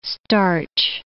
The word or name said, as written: starch